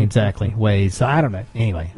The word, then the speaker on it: Waze: Yeah, it's got to be pronounced Waze, because it's Waze, the ways to go.